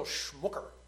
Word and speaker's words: Schmucker: There was a man named Samuel Schmucker. Not quite the same as Schmucker, the jelly people, but very similar, pronounced the same.